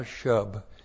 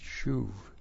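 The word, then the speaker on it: shuv (שוב): Sheen, not a scene. S-H long U-B, shuv. That's not shub, it's shuv.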